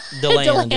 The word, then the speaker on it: DeLand: I'm like, that's how they say it? – DeLand, yeah.